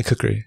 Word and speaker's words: kukri: Yeah, kukri.